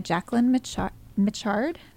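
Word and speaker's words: Jacquelyn Mitchard: It's by Jacqueline Michard, I believe is how you say her name.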